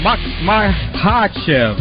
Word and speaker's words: Makhachev: Islam Makhachev, that K is silent.